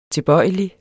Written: tilbøjelig

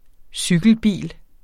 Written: cykelbil

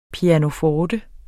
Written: PIANOFORTER

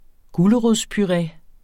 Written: gulerodspuré

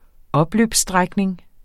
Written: opløbsstrækning

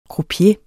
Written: croupier

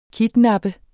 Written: kidnappe

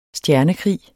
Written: stjernekrig

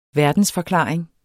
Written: verdensforklaring

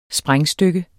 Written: sprængstykke

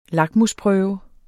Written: lakmusprøve

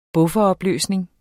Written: buffer

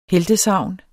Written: heltesagn

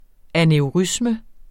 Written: aneurisme

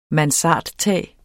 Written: mansardtag

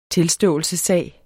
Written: tilståelsessag

